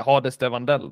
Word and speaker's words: Hades de Vandell: Om, om den då, eh, ja hur man uttalar det, Hades de Vandell då. – Så säger man. Helt rätt uttal.